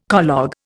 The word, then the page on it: collogue: Indian